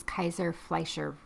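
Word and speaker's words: Kayser-Fleischer: So one unique sign of Wilson disease is Kaiser-Fleischer ring. Is that right? – Yes. – Am I saying that right? – I think so, yes.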